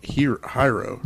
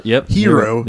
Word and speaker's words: Hiro: You might know him for the voice of, I think I'm saying this wrong, Hiro. – No, Hiro. – Hiro. There you go.